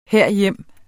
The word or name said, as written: herhjem